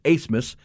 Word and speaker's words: Abmas: Max Asmus, and we've told you it's spelled Abmus, A-B-M-A-S, but it's a German name, and so it's Asmus.